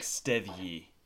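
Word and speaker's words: Steðji: I think this would be like Stethji. – It's like, got a letter in there that I've never seen before. – Yeah, that goofy like, D is pronounced like a TH.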